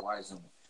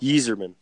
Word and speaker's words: Yzerman: Yzerman or Wiseman. Depends on what the dialect. – Yeah. – Some people like Yzerman.